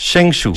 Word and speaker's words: Zhengzhou: Zhengzhou, ¿está bien pronunciado? ¿Cómo se dice? – Zhengzhou.